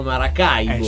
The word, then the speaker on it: Maracaibo: l'accento sulla I di Maracaibo. – Perché in realtà si chiama Maracaibo.